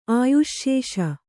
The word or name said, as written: ಆಯುಶ್ಶೇಷ